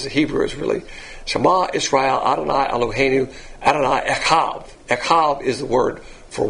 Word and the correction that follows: Echad: And if you get the Hebrew pronunciation, it's Echad. You got to kind of spray it out.